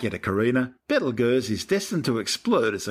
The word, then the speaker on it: Betelgeuse: Well, that's the red supergiant Betelgeuse, better known to most people these days as Betelgeuse. Don't say it three times. In ancient times, before centuries of mispronunciation, the name started out as Yptalgalza.